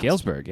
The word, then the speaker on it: Galesburg: How's that pronounced? – Galesburg, yeah. – Galesburg, mm-hmm.